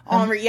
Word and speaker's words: Henri: Henri, yeah. – Henri. – Henri Matisse, Henri, if you're in France.